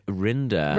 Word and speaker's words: Rinder: Yeah, and it literally shows, the only show that's on there is like Judge Reiner. – Oh, Judge Rinder. – Rinder, Reiner, Rimmer, whatever. – Thank you. Made it rhyme with grinder there.